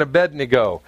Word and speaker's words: Abednego: We're going to see Shadrach, Meshach, and Abednego. Now, Abednego, because some people go, how do you say that? It's A-bed-N-E-go. Abednego. Abednego.